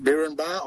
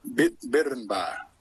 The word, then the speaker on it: Birrenbar: It's pronounced Birrenbar or Birrenbar. It all depends how you slur your tongue and I suppose how you spell it too.